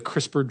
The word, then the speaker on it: CRISPR: So it's pronounced CRISPR like the CRISPR drawer in your refrigerator.